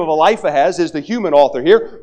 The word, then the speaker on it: Eliphaz: It's Eliphaz, but I call him Eliphaz. All right. So if you go, who is Eliphaz? It's Eliphaz. I just mispronounced it.